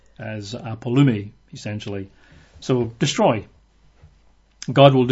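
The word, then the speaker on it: apollumi: It's apolumi. I'll spell that. It's A-P-O-L-L-U-M-I. A-P-O-L-L-U-M-I. Apolumi. And that's just the same word as before, but this is a verb, to destroy.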